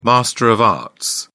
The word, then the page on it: master of arts: British English